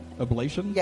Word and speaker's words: ablation: Ablation? – Yes.